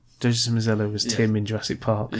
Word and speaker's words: Joseph Mazzello: Macniella. The guy who's playing Slade in Justice League. – Joseph Mazzello.